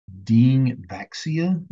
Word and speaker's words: Dengvaxia: It's called Dengvaxia, D-E-N-G-V-A-X-I-A, Dengvaxia. I think I'm pronouncing it correctly.